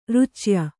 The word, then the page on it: ರುಚ್ಯ: rucya